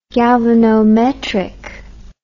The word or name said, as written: galvanometrically